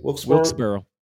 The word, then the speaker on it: Wilkes-Barre: I think they were Wilkes-Barre. That's how you pronounce it? – Wilkes-Barre. I'm using my New York accent. Yes.